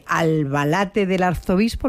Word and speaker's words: Albalate del Arzobispo: Pero bueno, llegas a este pueblecito de Albalate del Arzobispo, ¿lo he dicho bien? – Sí.